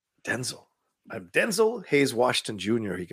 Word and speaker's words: Denzel: It's actually pronounced Denzel. Denzel, not Denzel.